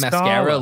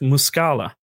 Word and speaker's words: Muscala: Are you saying mascara like a lady puts like on mascara? – No, it's Mike Muscala.